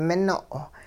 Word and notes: mino': hupa

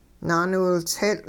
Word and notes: naaniwiltseetL': hupa

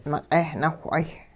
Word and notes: miq'eh-nawh'ay: hupa

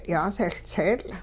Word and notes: yaasehLtseetL': Hupa

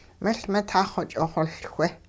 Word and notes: miL-mitahxw-ch'ixoLchwe: hupa

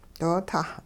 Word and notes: doo-tah: hupa

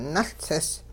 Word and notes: nuLtsis: Hupa

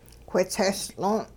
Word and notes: whitsestl'oon': hupa

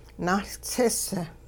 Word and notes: nahLtsis: Hupa

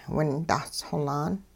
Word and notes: windahs xolaan: hupa

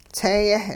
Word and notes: tsee-yeh: hupa

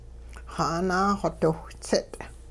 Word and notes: xaanaaxodiwhtsit': Hupa

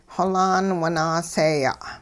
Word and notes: xolaan winaaseeya': hupa

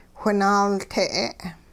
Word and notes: whinaalte': Hupa

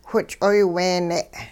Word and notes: which'oyweene': hupa

